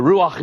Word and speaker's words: ruach: I'm going to spell that word for you. R-U-A-C-H. R-U-A-C-H. Ruach.